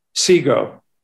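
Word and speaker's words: Sego: It's actually Seago. – Yes, Seago. Tell us Seago. – How do you spell that? – S-E-G-O, I think.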